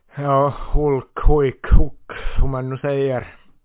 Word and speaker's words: Hulk: Nästa namn du får säg dina tankar om är Hulk, eller Huik som Elisa Palha i SVTs VM-studio 2014 fick oss att börja säga att han uttalas så.